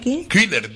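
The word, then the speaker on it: Güler: Killer, se pronuncia, por lo visto.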